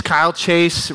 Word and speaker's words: Kyle Chase: If you don't know me, my name is Kyle Chase. It rhymes with smile face if you need help remembering.